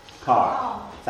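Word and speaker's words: Ker: Kerr chose, I never know how to pronounce, is it Kerr or Carr? Carr. Thank you.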